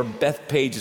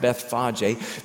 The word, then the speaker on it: Bethphage: Now they go about 1 mile to another community called Bethphage, or Bethpage is what I used to say, but this week I found out it's actually pronounced Bethphage, B-E-T-H-P-H-A-G-E.